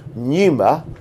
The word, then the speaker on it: pneuma: And then in the New Testament, the pun also works, because the word in the New Testament for breath is pneuma, spelled with a P, from which we get pneumatic drill.